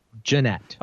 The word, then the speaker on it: Gennett: Gennett. Right. Which one is it? – Gennett. – Okay, so guys like Gennett. – Think of Marty Jannetty.